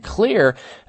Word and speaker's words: Xlear: And Clear, is the reason it's spelled X-L-E-A-R is the X stands for xylitol, which has been proven to be one of the best nasal cleansers that you can use.